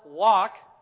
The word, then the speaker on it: walk: We have arguments at home about how to say C-A-U-L-K and W-A-L-K. It's not something you cook Chinese in, people. It has an L in it, right? I pronounce the L. Other people insist you don't.